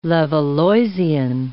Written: Levalloisian

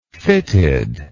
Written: fittedness

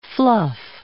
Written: fluff

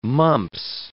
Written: mumps

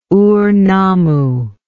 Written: Ur Nammu

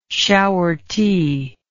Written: shower tea

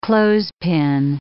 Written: clothespin

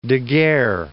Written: Daguerre

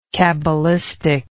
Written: cabalistic

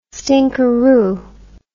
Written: stinkerroo

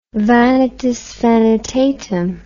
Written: vanitas vanitatum

